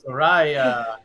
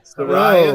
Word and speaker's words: Saraya: Sarara. How do you say it? – Saraya. – Saraya.